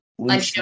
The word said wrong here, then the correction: Lencioni: Lencioni. – Lencioni. Okay, I always mispronounce his last name.